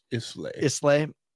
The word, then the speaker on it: Islay: But, Lagavulin, like, I once you discover the smoky scotches, like those Islay, however you pronounce it, pretty sure it's Islay.